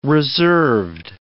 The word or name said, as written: reserved